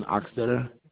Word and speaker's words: Achstetter: Jonathan Oxtetter. Last name is spelled Achstetter.